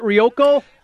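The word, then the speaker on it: Ruocco: Vitale, he's working with Ryan Rococo. Is that how you say it? Rioco? – Yes. Yep. That's it.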